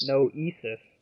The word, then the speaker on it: Noesis: Neosis, but I was told that's not how you pronounce it. It sounds like thesis, only without the TH, so ESIS. Is that how you pronounce it? – Noesis.